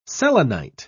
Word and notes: selenite: British English

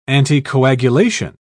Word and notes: anticoagulation: British English

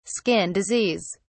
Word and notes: skin disease: British English

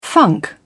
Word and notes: funk: British English

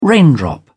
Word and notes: raindrop: British English

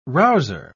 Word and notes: rouser: British English

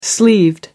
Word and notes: sleeved: American English

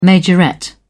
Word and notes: majorette: British English

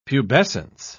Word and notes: pubescence: British English

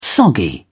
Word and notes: soggy: British English

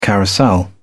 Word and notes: carousel: British English

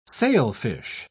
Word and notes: sailfish: British English

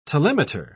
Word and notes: telemeter: British English

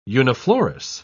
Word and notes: uniflorous: British English

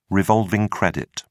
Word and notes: revolving credit: British English

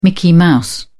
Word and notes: mickey mouse: British English

